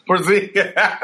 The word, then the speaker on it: Porzingis: He said Przinski. – What is it? – That old man's a Porzingis. – Porzingis. Porzingis. Yeah, I don't know, man.